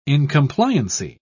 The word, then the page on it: incompliancy: British English